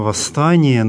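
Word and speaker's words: востани: «Восстани» значит «встань». Обратим внимание на то, что в отличие от русского слова «восстать», в церковнославянском «восстати» приставка здесь «во», а не «вос», поэтому одна «с» пишется в середине слова.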